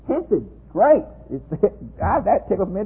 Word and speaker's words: hesed: The word hesed is a word that, when you add the plural on it, becomes the godly ones of verse 23.